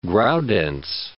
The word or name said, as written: Graudenz